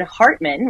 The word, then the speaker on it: Hartman: Hartman, which is my last name. That's spelled H-A-R-T-M-A-N.